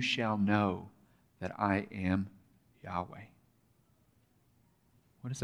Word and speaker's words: Yahweh: Most scholars, there seems to be consensus that it's pronounced Yahweh.